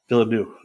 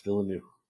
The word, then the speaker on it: Villeneuve: So I say Denis Villeneuve. – Villeneuve. That's right. Denis Villeneuve. – So maybe I'm wrong. Who knows? – Like a new house, Villeneuve.